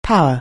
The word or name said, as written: power